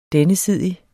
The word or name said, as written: DENNESIDIGE